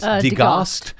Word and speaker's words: degaussed: Degaussed? How do you say that? – Degaussed. Yeah, we would say degaussed.